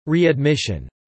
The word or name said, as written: readmission